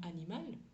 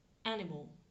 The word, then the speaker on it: animal: Par contre, à l'oral, ça va se dire animal. Animal. L'accentuation, l'accent tonique est sur ma première syllabe.